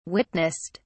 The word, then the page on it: witnessed: British English